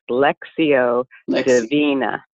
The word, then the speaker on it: Lectio Divina: And one of the things that I learned was about Lectio Divina. Am I pronouncing that correctly? – Pretty close. – Okay. – It's actually Latin, so it's pronounced Lectio Divina.